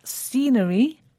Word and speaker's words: scenery: They were scenery, accommodation, and restaurants. – Scenery starts with a s sound, but it's spelt S C. It's quite easy to forget the C because you don't hear it.